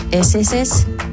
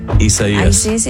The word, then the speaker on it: Isaias: Isis? – Isaias.